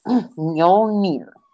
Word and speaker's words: Mjolnir: Okay, so I looked up how to pronounce the name. – Mew Mew? Go ahead. – It's Mjolnir. – Mjolnir. – Mjolnir. – Oh, yeah, I'm never going to get that. – It should sound like meow, but replace the W with an L.